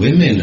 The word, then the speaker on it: women: It's W-O-M-E-N, but it's pronounced women. You don't pronounce woman. Well, individual, it's a woman, which is an O, which is a U, of course, but women.